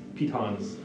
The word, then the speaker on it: piton: I could set like a perimeter of pitons. Apparently, it's pronounced piton. I just figured that out.